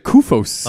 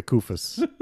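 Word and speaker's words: Koufos: I just love that his name kind of rhymes with doofus. Costa Kufos.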